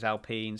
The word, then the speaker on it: Alpine: It's spelled Alpine, but the French pronunciation is Alpine.